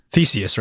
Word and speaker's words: Theseus: Theseus, right? – Mm-hmm. – That is the right way to pronounce it.